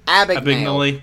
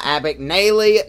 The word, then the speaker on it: Abagnale: Abagnale. Not Abagnale. Not Abagnale. Abagnale.